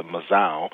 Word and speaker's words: mazao: which is the mazao, and that's spelled M-A-Z-A-O. It's placed on a mat called the mkeka, and that is M-K-E-K-A.